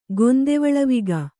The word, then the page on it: ಗೊಂದೆವಳವಿಗ: kannada